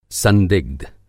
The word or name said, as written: संदिग्ध